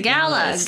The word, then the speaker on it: gala: You say gala and I say gala. Gala. – Are you kidding me? Do you honestly? You guys did not tell me you do that. Tell me you have a whole thing about that. – I just really actually don't know what's correct. – Both pronunciations are acceptable. – No one is wrong. – But I think if we're being very technical, it is gala.